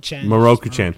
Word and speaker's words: Mieruko-chan: Mieruko-chan.